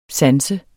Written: sans

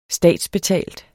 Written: statsbetalt

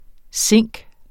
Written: zink

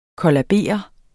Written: kollabere

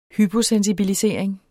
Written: hyposensibilisering

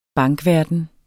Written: bankverden

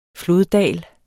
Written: floddal

